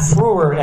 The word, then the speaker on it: Frewer: This is from the Pink Panther series that had Matt Frewer as the Friar, how it's pronounced, as the voice.